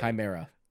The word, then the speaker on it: Chimera: Chimera. – Chimera. You think so? Did I say it wrong? That's, that's how it's pronounced.